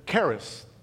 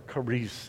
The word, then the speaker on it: charis: In fact, it still happens today in Greece, and they'll say to each other, charis, they pronounce it, or charis, as we might say it.